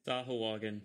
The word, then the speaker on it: Sahuagin: I'm going to butcher this. Sahogan? – Sahuagin. – Sahuagin. – Sahuagin. There we go.